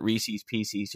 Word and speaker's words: Reese's Pieces: No, it's Reese's Pieces. Paul F. Tompkins even tweeted that. – Okay, thank goodness. – If you don't pronounce it Reese's Pieces, you're wrong.